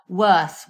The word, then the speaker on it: Wearth: There is a company called Wearth, which is like We Earth, in all in one word. So it's spelt W-E-A-R-T-H, Wearth London.